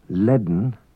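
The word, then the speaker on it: lane: An unwritten D is pronounced before N in some Manx words. Bedden, woman. Leaden, full.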